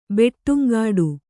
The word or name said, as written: ಬೆಟ್ಟುಂಗಾಡು